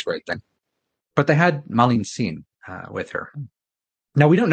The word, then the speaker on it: Malintzin: But Malintzin was Marina, pronounced in Nahuatl. There's no R sound, so it becomes an L. And then the Zin is an honorific.